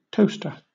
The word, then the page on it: toaster: British English